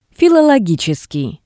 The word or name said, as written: filologitseskii